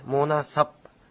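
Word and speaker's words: monasap: モナサプという、ちょっと、プがほとんど聞こえないような発音です。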